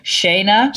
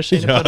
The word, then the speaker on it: Shayna: Shayna. Repeat after me. Shayna. Punim. – Punim. – Punim. – Punim. – Yeah. And it's Yiddish for pretty face. – I'm definitely not a Shayna Punim.